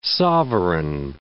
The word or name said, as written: sovereign